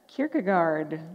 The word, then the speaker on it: Kierkegaard: Kierkegaard.